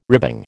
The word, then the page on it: ribbing: American English